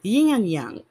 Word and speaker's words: yin and yang: The yings and the yangs. Did I say that correctly? Yin and yang.